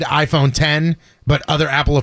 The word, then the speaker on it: iPhone X: The company said iPhone X is originally meant to be pronounced iPhone 10, but it is now known throughout the entire company and its users as iPhone X.